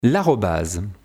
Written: l'arobase

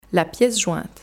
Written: la piece jointe

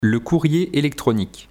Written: le courrier electronique

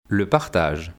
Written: le partage

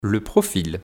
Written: Le profil

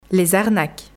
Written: Les arnaques

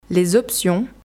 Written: les options

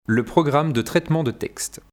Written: Le programme de traitement de texte

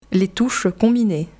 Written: les touches combinées

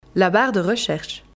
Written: la barre de recherche

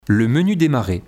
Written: le menu demarrer

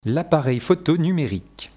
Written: L'appareil photo numérique

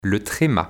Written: le trema

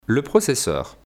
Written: Le processeur

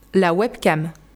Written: La webcam

